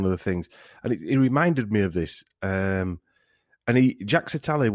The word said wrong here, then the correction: Jacques Attali: was, or Jack Satale, I better get my pronunciation right, or somebody will give me a rollicking again. Jacques Attali, is that better?